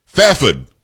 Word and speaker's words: Fairford: Fafid is what I was told how I should pronounce it from K.